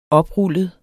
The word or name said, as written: oprullet